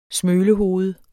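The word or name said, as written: SMØLEHOVED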